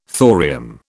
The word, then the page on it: thorium: British English